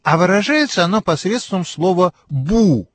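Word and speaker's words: бу (不): Обычно отрицание «бу» произносится, как вы слышите, именно с четвертым тоном. Но перед глаголом, который также имеет четвертый тон, это самое «бу» меняет свой тон на второй.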